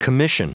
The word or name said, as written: commission